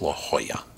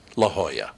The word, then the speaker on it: La Jolla: But I was lucky because Charlie Bush, just after I said it's actually pronounced La Jolla. So I went back on the air and I said, Charlie Bush said it's La Jolla.